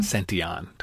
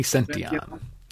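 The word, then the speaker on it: Santillan: Santion. Tony Santion.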